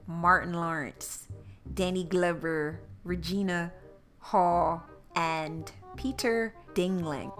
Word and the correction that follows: Dinklage: I'm always saying his name wrong. Peter Dinklage. Yeah, Peter Dinklage.